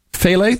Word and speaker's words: phthalates: How do you pronounce it? Phthalates? – Phthalates.